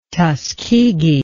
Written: tuskegee

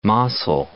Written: Mossel Bay

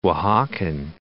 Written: Oaxacan

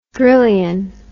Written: drillion

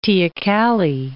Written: teocalli

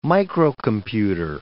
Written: microcomputer

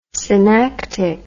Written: synactic

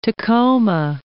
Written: Mount Tacoma

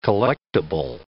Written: collectible

